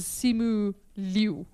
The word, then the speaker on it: Simu Liu: The interesting thing, though, is so the main guy, so Shang-Chi, his name in real life is Simu Liu.